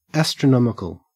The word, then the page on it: astronomical: Australian English